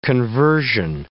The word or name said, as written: conversion